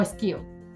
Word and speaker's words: SCIO: Yo lo, yo lo pronuncio como esquio, Esquío, ok.